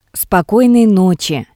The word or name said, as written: Spokoinoi notsi